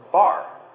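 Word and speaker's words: bar (בר): And the other two letters, the B-R, is pronounced bar, right?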